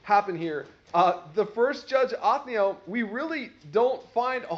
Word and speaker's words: Othniel: Othniel is how you say his name.